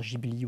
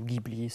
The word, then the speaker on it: Ghibli: par ailleurs, en termes de prononciation, pour rassurer les, spectateurs, on peut dire Ghibli ou Ghibli, ce pas, pas très très grave.